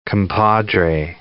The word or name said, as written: compadre